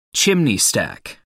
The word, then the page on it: chimney stack: American English